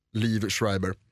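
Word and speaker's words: Liev Schreiber: Eh, bland annat då Lev Schreiber som jag lärde mig att han ska uttalas. Liv Schreiber.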